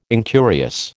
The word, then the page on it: incurious: American English